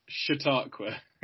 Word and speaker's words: Chautauqua: Chow Tapo, that's how you say it, isn't it? – Can we have a correct pronunciation, please? – Chautauqua. That was great.